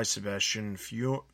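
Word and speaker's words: Sebastian Fiumara: Detective Comics number 982, written by Michael Morakai, art by Sebastian Fiormora. I know I said that wrong. – You got it. Nailed it. Well done.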